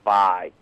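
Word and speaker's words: Vay: You're actually mispronouncing it, and I always have called it Bay as well. But the announcer in the open and cinematic actually pronounced it as Vi.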